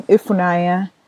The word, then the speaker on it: Ifunanya: I learned that in my name, my full name, Ifunaya, that the N-Y at the end of it, so it's spelled I-F-U-N-A-N-Y-A, that N-Y is a double consonant in Igbo. – Definitely, yeah, you're correct. My name also, Uchoma, has an Udamkpi, so U-C-H-E-O-M-A.